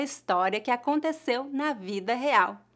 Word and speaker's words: real: Real, real, real. Note que a letra R em início de palavras é pronunciada na garganta, mais ou menos como a letra H no inglês, real real.